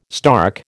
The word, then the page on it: stark: American English